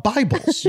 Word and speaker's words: Bible: So when you thought I'm going to rent out Bibbles, you were originally going to rent out Bibles. – Yes, but I mispronounced it because, you see, I'd only read the word Bible. – Yes, of course. – And so I thought it was pronounced Bibbles.